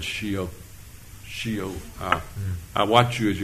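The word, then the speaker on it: Shio (ᏏᏲ): S in the syllabary, it's S-I-Y-O. But the S is pronounced Shio.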